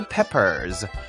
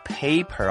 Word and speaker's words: pepper: 辣椒叫做 chili peppers, C-H-I-L-L-I, 这是第一个字 chili, 第二个字叫做 pepper, pepper, P-E-P-P-E-R, 不要念作 paper 哦， paper, P-A-P-E-R, 表示纸，这里念作 pepper, pepper.